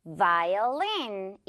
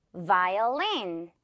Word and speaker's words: violin: 이 발음도 바이올린이 아니라 바바바 아니고 바바바. 오케이. 요거 V 발음 살려서 Violin. 이렇게 발음이 돼요. Violin.